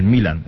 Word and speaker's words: Milan: Y el AC Milán es un nombre de equipo, el Milan, es como el Real, el Milan.